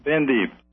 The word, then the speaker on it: Bendib: And help me with the pronunciation. Do you say Bendib or Bandib? – Bendib. – Bendib. Thank you.